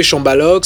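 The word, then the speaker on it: Chambalock: Et bah, vous tapez Chambaloc c'est C H A M B O. – non, M-B-A-Lock, L-O-C-K. Chamba, comme ça se prononce.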